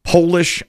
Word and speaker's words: Polish: It's the only word that when you change it from being lowercase to capitalized, it changes its meaning and its pronunciation. It'd be hard to think of. It's Polish and polish. It's spelled P-O-L-I-S-H, Polish and polish.